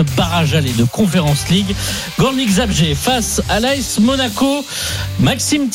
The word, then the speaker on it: Górnik Zabrze: Belle prononciation d'ailleurs Arnaud pour le Górnik Zabrze.